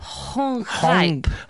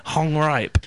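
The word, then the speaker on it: Gonggrijp: It's Rob Gongriep. – I think it's Rob Hongripe. – Hongripe. Okay, fair enough.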